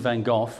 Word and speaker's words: van Gogh: Yeah, I mean, one of the key things is that if you think of it in history, Vincent van Gogh or Van Gogh, depending on how you want to pronounce it, in his lifetime, he sold $2,000 worth of paintings.